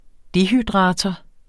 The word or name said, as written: dehydrator